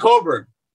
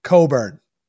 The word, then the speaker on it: Cockburn: Kofi Coburn. – Coburn. – Coburn. – Yeah, because I didn't read it like that. – The CK is silent. – For those that don't know, it's spelled Cockburn.